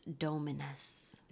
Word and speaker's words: Dominus: The Latin word for Lord, which is pronounced Dominus.